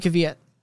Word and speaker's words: Kvyat: I don't even care that Daniel Kvyat, is that how you pronounce his name, Kvyat? – Yeah, Daniel Kvyat.